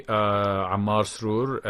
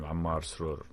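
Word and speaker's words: Ammar Srour: So if people want to reach me, Ammar Sroor at LinkedIn.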